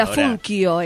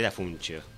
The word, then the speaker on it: Dafuncio: Y el apellido es como la tragedia de muchos inmigrantes que vinieron. En italiano se escribe y se pronuncia de otra manera. – Dafuncio es. – Dafuncio, bueno. Dafuncio. Claro. Ahora, cuando llegaron en barco, los anotaron como sonaba. Así que soy Dafuncio.